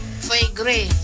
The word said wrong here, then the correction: foie gras: Hati angsa gras. Bacanya foie gras.